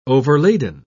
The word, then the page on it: overladen: British English